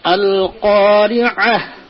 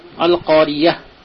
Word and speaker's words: القارعة: সেক্ষেত্রে আইনটাকে আইন আর হা এই দুটোকে খুব ভালো করে ইয়ে করতে হবে। আল কারিআহ আইন যদি না উচ্চারণ করে তাহলে ইয়া হয়ে যাচ্ছে, আল কারিআহ।